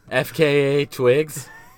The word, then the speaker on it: FKA Twigs: Maybe it reminds me of a little, I'm not sure if I'm saying this right, like a fuck a Twigs. – FKA Twigs? – Yeah.